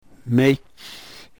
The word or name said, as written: mech